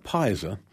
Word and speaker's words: Pisa: Mount Pisa, now you say it with a long I, not like the city in Italy. Mount Pisa lies on the west side of the Clutha River.